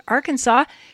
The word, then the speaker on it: Arkansas: states that Arkansas should be pronounced in three syllables, with the final S silent, the A in each syllable with the Italian sound, and the accent on the first and last syllables.